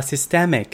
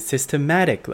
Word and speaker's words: systemic: 第一个单字是 systemic，s y s t e m i c，systemic，结构性的是形容词。 Protesters say the police brutality is systemic。敏锐的听众可能发现，诶，这不是跟上周五的例句一样吗？因为上周五我口误，把 systemic 念成 systematic 了。